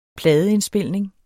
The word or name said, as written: pladeindspilning